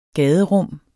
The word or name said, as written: gaderum